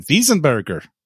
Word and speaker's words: Weisenberger: the Weisenberger Mill, or if I was to say it correctly in German, Wiesenberger.